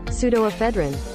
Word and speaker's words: Pseudoephedrine: Pseudoephedrine.